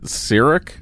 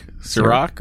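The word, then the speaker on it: Cirroc: Even when the lines are dumb, Avery Brooks and Sharok Lofton, is that how you say his name? – I've always pronounced it Sirik, Sirok, or Sirok. I've never had an SH sound on there.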